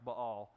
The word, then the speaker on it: Baal: In Hebrew, it would be pronounced Baal.